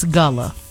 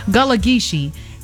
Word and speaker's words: Gullah Geechee: From the roots of it's Gullah. Right, I said it right this time. Gullah Geechee.